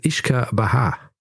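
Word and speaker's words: uisce beatha: Das Irische heißt übrigens Ishka Baha. Also das ist Ishka Baha, aus dem wurde dann Whisky. – Ist die Aussprache gesichert? – Die Aussprache ist gesichert, weil ich habe YouTube aufgemacht.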